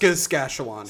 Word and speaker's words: Saskatchewan: Yeah, it's pronounced Saskatchewan.